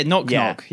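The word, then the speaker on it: Gnoc Gnoc: Is it Knock Knock? G-N-O-C. – Yeah. Knock Knock.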